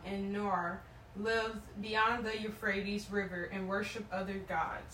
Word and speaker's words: Euphrates: Long ago, your ancestors, including Terah, the father of Abraham, and Noah, lived beyond the Euphrates. Is that how you say it now? – Euphrates River, and worship other gods."